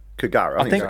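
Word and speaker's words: K'gari: Kigari. I think it's actually, I think the K is silent.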